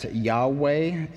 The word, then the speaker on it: Yahweh: If you've ever heard the name Jehovah, that is where the name comes from, that idea. Most scholars today understand how to pronounce that as Yahweh.